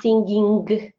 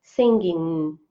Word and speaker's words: singing: I tu też jest taki myk z tą wymową, to nie jest tak, że to jest singing, to G nie jest takie mocne, to jest bardziej coś takiego, że singing. Mm. To jest takie ny gardłowe, takie bardziej mm. Taki dźwięk, się chciały powiedzieć ny, ale tego ny nie wymawiacie tak do końca, tylko ono zostaje w waszym gardle.